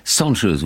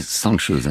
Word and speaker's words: Cansız: Cansız, vous prononcez Cansız. Hein. – Cansız. – Cansız. Et ça s'écrit Cansiz. Alors, moi, j'ai prononcé à la française. – Bien sûr. – Hein. Mais ça se prononce comme ça, d'accord.